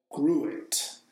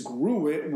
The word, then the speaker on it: gruit: So for thousands of years, the dominant form of beer was called gruit. I guess that's how it's pronounced. It's G-R-U-I-T.